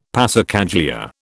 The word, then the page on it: passacaglia: British English